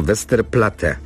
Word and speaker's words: Westerplatte: Otóż Westerplatte to nazwa pochodząca z języka niemieckiego i z niemiecka powinna być wymawiana, to znaczy przez jedno T w ostatniej sylabie, zatem Westerplatte.